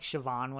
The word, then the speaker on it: Siobhan: Her name is, I'm pretty sure it was Siobhan, is how they pronounced it. – Yeah, it sounded like Siobhan when they said it.